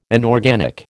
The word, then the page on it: inorganic: American English